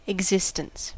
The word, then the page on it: existence: American English